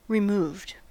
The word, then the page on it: removed: American English